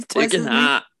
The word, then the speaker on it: Tugendhat: Tugendhat came across to me as did I say that right? – No, who's Tugendhat? – I don't know, is that not his name? – Tugendhat.